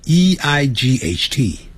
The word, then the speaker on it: eight: 八 e i g h t，那个好念 g h 不发音，念成 eight eight eight。